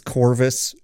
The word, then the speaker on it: Corvus: Or maybe it's Corvus. It's spelled with a V, but it's like Chorus with a V.